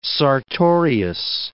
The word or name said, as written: sartorius